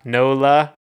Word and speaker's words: NOLA: Yep, they're from Nala, Nola. I saw it N O L A, which is just New Orleans, Louisiana. – Nala. – Nah, NOLA. – Because I think because you pronounce it NOLA and it's like nah.